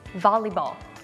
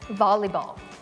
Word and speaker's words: Volleyball: แต่จริงๆแล้วมันออกเสียง Lee Lee Volleyball Volleyball Volleyball